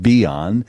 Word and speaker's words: Bian: It's spelled B-I-A-N. Let's pronounce it Beyond.